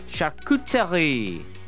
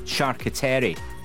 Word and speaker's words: charcuterie: Well, apparently, acai, as in the berries, you know, the healthy stuff, A-C-A-I, that's how you say it, supposedly. Seg, not sig. And charcuterie. You're supposed to say it with a French accent, not charcuterie.